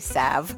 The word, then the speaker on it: salve: It's S-A-L-V-E. – Hang on. Salve. – Do you pronounce the L in salve? In some parts of the world, people pronounce salve the way it's spelled, but in the U.S., the current pronunciation has a silent L.